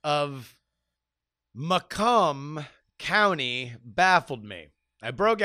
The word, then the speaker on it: Macomb: And then it hit me, what I had pronounced as Macomb, I guess it was like Macon, Georgia for me, is indeed pronounced Macomb. Said much like Macomb, thanks to the inundation of the French in the early days of colonization.